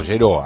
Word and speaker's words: Jhero: Se escribe J-H-E-R-O, Gero, así es el título, el nombre, perdón, del cantante que estamos escuchando en este momento.